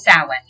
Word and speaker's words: Samhain: And it's spelled all funky in traditional Irish fashion or Celtic fashion, I should say. Samhain. Samhain. Most people are like, none of those letters equal what you just said.